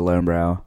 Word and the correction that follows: Löwenbräu: I'm not pronouncing that right. – Löwenbräu.